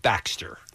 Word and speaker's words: Baxter: Last name, nobody ever seems to have heard of the name Baxter. – But who cares? – It seems like it's an extremely phonetic name that's easy to spell. It's spelled exactly the way it sounds.